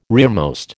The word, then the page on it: rearmost: American English